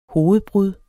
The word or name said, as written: hovedbrud